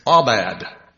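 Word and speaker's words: Abad (עבד): The word in Strong's Concordance is number 5647, and it's Abad, A-W-B-A-D, with the accent on the B-A-D.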